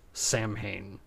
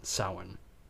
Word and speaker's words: Samhain: Generally, it's pronounced Samhain and not how you would pronounce the holiday, as in Samhain. At some point, Samhain starts actually getting a little bit of attention from record companies, saying, do we want something to do with this?